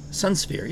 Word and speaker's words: Sunsphere: Sunsphere. Is that how you say it? – Sunsphere, yeah. – Sunsphere. – Yeah, it's like suns and then fear, like you're in fear.